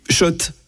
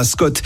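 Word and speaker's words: Schott: Oui, Shot et pas Scott, ça fait des années qu'on prononce mal la marque.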